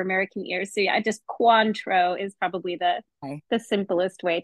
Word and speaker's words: Cointreau: Is it Cointreau? – It's Quan, like Kwanzaa, I guess is okay. Like the most American. I mean, we could go really French, we say Cointreau.